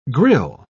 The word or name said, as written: grille